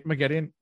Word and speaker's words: Moghedien: Now, why is Mogedion watching them from up high? – Mogedion, yeah, you said it right.